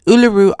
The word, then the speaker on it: Uluru: This time, I don't believe I'll be filming shows there, but I will be giving a presentation in Uluru, to be exact. It's taken me quite some time to get that pronunciation right. Uluru, Australia, right smack in the middle of this big country.